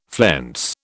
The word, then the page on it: flense: British English